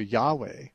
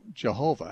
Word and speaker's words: Yahweh: Probably the Hebrew pronunciation would be much closer to Yahweh than to Jehovah,